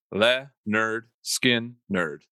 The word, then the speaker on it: Lynyrd Skynyrd: August 13th, 1973, Lynyrd Skynyrd released their debut album, literally titled, pronounced, Le Nerd Skin Nerd.